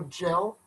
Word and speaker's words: Jelle: It's spelled J-E-L-L-E, so gel. – J-E-L-L-E. – Apostrophe S. Marble Runs. – M-A-R-B-L-E. – Runs. – R-U-N-S.